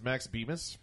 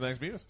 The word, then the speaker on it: Bemis: That's actually written by the band Say Anything's Max Bemis. For some reason, I like his last name because it rhymes with Beavis. – Well, his name is Max Bemis, but he actually—I don't know if he came up with a concept, but apparently he turned the Centipede game into a book.